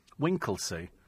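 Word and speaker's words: Winchelsea: It's apparently pronounced Winkleseel. Is that the... Winklesea? Is that what he said? Oh, Winklesea, sorry, it's an exclamation mark. Winklesea.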